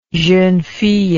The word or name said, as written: jeune fille